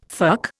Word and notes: fuck: Indian

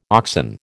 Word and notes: oxen: American English

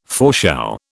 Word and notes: foreshow: British English

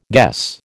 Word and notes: guess: American English

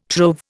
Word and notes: trove: Indian

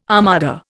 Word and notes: armada: Indian

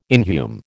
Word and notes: inhume: American English

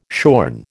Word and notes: shorn: American English

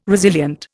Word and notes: resilient: Indian

